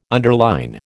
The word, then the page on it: underline: American English